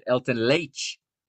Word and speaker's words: Helton Leite: And shout out to to to my new friend there, uh, my new friend Nino Torres, uh, pronouncing it Helton Leite. He's got the Brazilian pronunciation there,